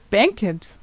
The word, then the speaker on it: Bankhead: Ach Gott, hier, Bankhead. – Bankhead, so spricht man es aus.